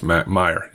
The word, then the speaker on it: Mayer: Is it Mayer or Meyer? – I think it's Meyer, yeah.